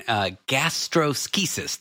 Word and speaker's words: gastroschisis: So this condition, gastroschisis. Did I say that right? – Said it perfectly.